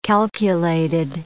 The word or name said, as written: calculated